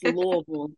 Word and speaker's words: Louisville: So I was like, what is Louisville? – Ooh, it's Louisville.